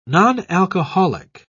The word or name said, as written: nonalcoholic